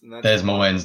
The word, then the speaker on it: Des Moines: Des Moines.